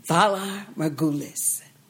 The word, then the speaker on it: Valar Morghulis: I can't even say it right. – I can. Valar Morghulis.